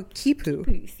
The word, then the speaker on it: quipu: Oh, quipu.